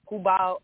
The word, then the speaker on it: Cuba: You know, I should be saying Cuba. That's the correct way to say it, but I'm saying it my anglicized way.